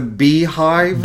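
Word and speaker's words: Beyhive: The beehive? – Beehive. Well, it's pronounced Beehive, like Beyonce, but it's B-E-Y-H-I-V-E.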